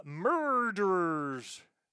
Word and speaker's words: Myrrhderers: And that is a super group of sorts doing a Christmas-themed thing, and it's spelled M-Y-R-R-H-D-E-R-E-R-S, if you're wondering why I was pronouncing it the way I was.